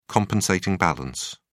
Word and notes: compensating balance: British English

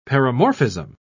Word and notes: paramorphism: British English

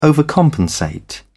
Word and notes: overcompensate: British English

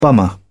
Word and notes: bummer: British English

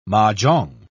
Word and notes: mah-jongg: British English

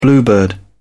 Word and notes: bluebird: British English

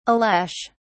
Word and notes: ales: British English

